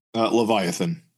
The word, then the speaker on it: Leviathan: Leviathan. Emphasis on the Vi part.